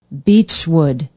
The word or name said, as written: beechwood